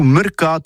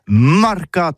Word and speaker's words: marcats: Mais en gascogne toulousaine, c'est prononcé sur les marcats, avec un A. Marque. En gascogne toulousaine, bah, du côté, par exemple, de l'île des Bâches, c'est l'île Jourdain. Pour parler du marché, vous prononcez les marcats.